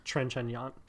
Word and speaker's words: Trintignant: Yeah, Trintignant.